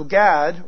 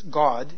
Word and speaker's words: Gad: And so Gad, or you could actually pronounce this God,